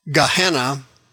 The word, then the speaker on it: Gehenna: Gehenna is pronounced in English Gehenna, that's the lake of fire.